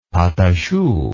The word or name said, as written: pateachou